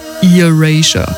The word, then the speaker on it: Erasure: Sie kommt aus Großbritannien und manchmal wird sie Erasure ausgesprochen und manchmal Erasure. Hier die Lösung. Es ist Erasure.